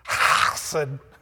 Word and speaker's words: hesed: The word there in the Hebrew is hesed. If you really pronounce it good, like some of my professors, it's hesed. Sounds like you're... You know, it's one of those real guttural things.